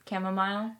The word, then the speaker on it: chamomile: You said, how did you pronounce it? I said, chamomile.